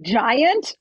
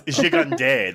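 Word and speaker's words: Gigandet: I'm like, giant? – No, it's Gigande. That's the French way.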